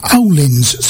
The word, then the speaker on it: Oulins: She's actually texted me back and said, I hear you trying to pronounce the area I'm from, which is, and she said, you're quite right, it's called Oulins, spelled O-U-L-I-N-S, and she says, if you want to look at it on a map, we are about 15 miles away from St. Etienne.